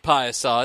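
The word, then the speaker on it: Piasa: I'm from Fillmore, Illinois, which is about an hour outside of Piasaw, and it's pronounced Piasaw. It's P-I-A-S-A. It looks like Pisa. – Pizza. – But it's Piasaw.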